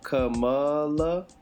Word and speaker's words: Kamala: I don't want to mess this up. Kamala.